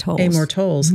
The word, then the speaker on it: Amor Towles: Amor Towles, mm-hmm.